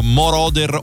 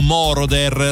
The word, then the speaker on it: Moroder: Giorgio Moroder o Moroder, l'accento mettetelo come sempre dove volete, nel